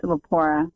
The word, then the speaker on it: Lepora: Lepora.